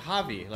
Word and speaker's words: Javi: No, I said Javi. Like the J is pronounced like an H, right? – No.